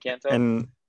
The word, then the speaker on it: Encanto: Best motion picture animated, Encanto. I think that's how you say it. Encanto. – Yep. – Encanto.